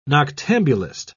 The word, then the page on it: noctambulist: British English